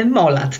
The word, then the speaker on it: Molat: E, zgodziłaś się mi o niej opowiedzieć Molat, dobrze wymawiam, czy powinno się wymawiać to z innym akcentem? – Molat, tak, Molat.